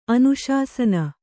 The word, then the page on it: ಅನುಶಾಸನ: anuśasasana